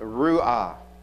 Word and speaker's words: ruah: This word joy is pronounced ruah. You know, you might think of cheerleaders going hurrah or something of that sort. Ruah, joy.